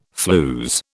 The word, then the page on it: flews: British English